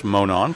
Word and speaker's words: Monon: That's actually pronounced Monon for you.